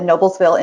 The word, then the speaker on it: Noblesville: Middle school in Noblesville, Indiana.